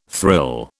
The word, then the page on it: thrill: British English